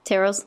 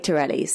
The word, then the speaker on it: Tyrrell's: Tyrrell's. – Thank you. – How else would you say it? – I say Tyrrelli's.